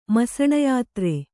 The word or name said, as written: ಮಸಣಯಾತ್ರೆ